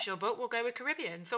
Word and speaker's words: Caribbean: Caribbean. I remember talking about this at school, but yes, it can be either or. I call Caribbean.